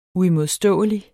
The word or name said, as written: uimodståelig